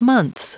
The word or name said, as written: months